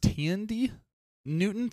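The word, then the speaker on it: Thandie Newton: I think her name, it's pronounced Tandy Newton.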